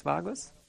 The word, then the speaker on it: vagus: The nervus vagus, correct? The anatomy, people? That is the biggest nerve. Pun? Biggest nerve. Vagus. Thank you. That's how you say it in English.